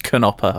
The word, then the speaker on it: Knopper: Does that say Nopper? – on a Knopper. It's pronounced Knopper, I found out.